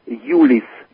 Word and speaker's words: Youlis: Y-O-U. – Okay. – L-I-S. – So the pronunciation in Russian and Yiddish is Youlis.